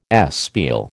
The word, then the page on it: espial: American English